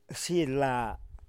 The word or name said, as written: Xila